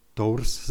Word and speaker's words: Tours: století je znám jeden světec nebo člověk, který teda pracoval pro Pána silně a byl to evangelista, jmenoval se Martin Tours se to píše, je to Tour, se to vyslovuje, vlasto, jo, francouz to byl.